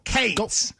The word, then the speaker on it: Cats: Really, it's "Go Cats," but we in Kentucky would go "Go Cates." – Go Kayatsu. – You're adding a syllable. It's like one syllable. Cates.